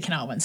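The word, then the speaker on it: Kurniawan: Well, he pronounces it as Rudy Canard one. Rudy Canarwin.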